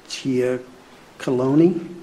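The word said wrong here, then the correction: Giacoloni: Oh, Jackaloni. – Yeah, Jackaloni. I knew I'd say it wrong.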